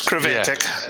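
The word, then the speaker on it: Creventic: Yeah, the iRacing Kvantic. Did I say that right? – I don't know. – Kreventic. – It's Kreventic.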